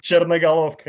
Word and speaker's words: Chernogolovka: You said Chernogolovka. – Chernogolovka, yes.